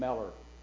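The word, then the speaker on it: Müller: I've been saying Mueller all my life. He's pronounced his name Meller. He's got to be the expert at what his last name is.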